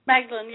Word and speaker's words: Magdalene: Did I say the last name correct, right? Magdalene? – Magdalene, yes, absolutely.